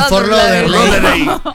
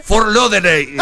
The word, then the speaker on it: Fort Lauderdale: El panadero de Fort Lauderdale. – Lo escuché tantas veces que ahora creo que lo digo bien. – A ver. – Fort Lauderdale. – ¡Ay, no, casi! No. ¡Casi! – Fort Lauderdale.